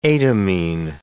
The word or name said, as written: etamine